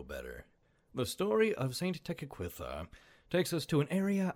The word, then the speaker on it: Tekakwitha: I looked up the actual pronunciation, which is, oh gosh, Tekakwitha. But that's super difficult for me to say consistently. So, I am going to go back to the standard pronunciation.